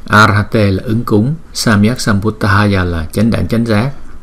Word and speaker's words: Sambuddhaya: Chữ D và cái hơi nhiều một chút chúng ta đưa ra Samyak Sambuddhaya, Samyak Sambuddhaya.